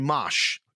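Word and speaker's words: Mache: A guy named Mosh. Okay, it sounds like Mosh Pit. That's how you pronounce it, but it's M-A-C-H-E.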